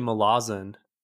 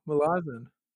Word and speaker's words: Malazan: Yeah, quick correction there. It's actually Malazan. – Oh, Malazan. – Yeah, often mispronounced, but I listened to an interview with Steven Erickson. He said Malazan, so we're going with that.